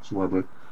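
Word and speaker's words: Swarbrick: Is it Schwarbrick? Is that how you pronounce it? – Yeah, Schwarbrick.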